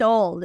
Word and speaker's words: old: No, ah, ah, she's old, es, ella es mayor, es una palabra respetuosa, está bien. Old, asegúrense de pronunciar la final. Old, y que suene la de final, porque si no parece que dijéramos all.